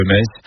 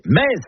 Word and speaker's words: Metz: Le meilleur moyen de mémoriser la prononciation correcte de la ville de Metz est certainement de se rendre au Stade Saint-Symphorien, où vous répéterez jusqu'à plus soif. Ici c'est Metz.